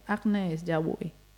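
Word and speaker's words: Agnès Jaoui: Le francesa concretamente, Agnes Jaoui.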